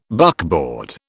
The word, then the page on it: buckboard: British English